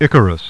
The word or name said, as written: Icarus